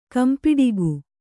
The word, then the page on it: ಕಂಪಿಡಿಗು: kampiḍigu